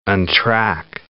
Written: untrack

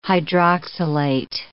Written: hydroxylate

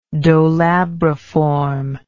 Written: dolabriform

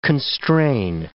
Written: constrain

